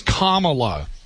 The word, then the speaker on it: Kamala: It's Kamala. It's Kamala. Like Kamala. Kamala. Yep.